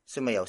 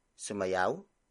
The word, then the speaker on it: sumayaw: For example, sumayaw si Chris. Sumayaw si Chris. Sumayaw si Chris. It means Chris danced. Word for word it's sumayaw si Chris.